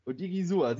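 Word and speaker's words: Odighizuwa: Kannst du das? Osa Odigizua. Meinst du Oda Osa Odigizua? – Odigizua.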